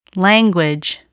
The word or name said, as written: language